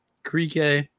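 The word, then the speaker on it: Krikke: But Ben Krieke, I figured out that's how you pronounce his name apparently today, because I watched the broadcast.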